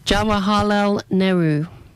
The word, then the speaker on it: Jawaharlal Nehru: Jawaharlal Nehru.